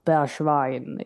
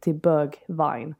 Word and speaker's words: Bergwijn: Och givetvis då det fantastiska avslutet från Steven Bergwijn. Nu uttalar jag det med oerhört engelskt uttal. Jag pratade med någon holländare som uttalade lite mer sådär Bergwijn. Ja. Men jag tänker att jag håller mig till Bergwijn.